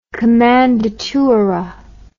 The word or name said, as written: kommandatura